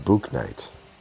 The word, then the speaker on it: Bouknight: I believe it's pronounced Bouknight. – More like Spook Night. – Book Night. Well. Book Night? Yeah. Kai Jones, JT Thor, and Scotty Lewis. – JT Thor? Is that spelled G-H-O-R? – It is.